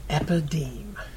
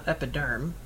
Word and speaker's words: Epideme: E-P-I-D-E-M-E. – Epideme. – Yep. Yeah. – Epideme. Not epiderm. So kind of like epitome, but with things transposed.